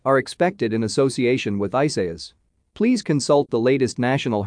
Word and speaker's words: Isaias: All eyes remain fixed as well on newly formed Tropical Storm Isaias, pronounced Isaias, which is currently south of Puerto Rico and moving to the Northwest.